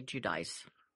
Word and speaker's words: Giudice: Well, it's a different name in Italian than it is. They say Giudice.